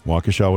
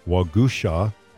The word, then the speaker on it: Waukesha: Waukesha was pronounced by the Chippewas as Waugusha, meaning little fox.